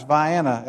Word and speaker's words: Vienna: It spelled like Vienna, but Adam called it Vienna, and he's right. It's Vienna, Illinois. I call it Sausage City, but they call it Vienna.